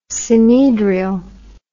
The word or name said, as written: synedrial